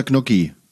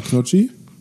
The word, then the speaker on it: Gnocchi: Knocchi oder Gnocchi? – Also die Rottgauer sagen Knocchi, die richtige Rottgauer sagen Knocchi, aber der normale Mensch sagt Gnocchi.